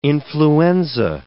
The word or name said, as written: influenza